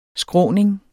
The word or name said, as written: skråning